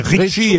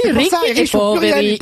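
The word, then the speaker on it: Ricchi e Poveri: Bah oui, Ricky et Poveri. Et si, il y a l'accent tonique Poveri.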